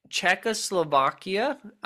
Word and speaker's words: Czechoslovakia: Okay, in Czechoslovakia, I hope I'm saying that right.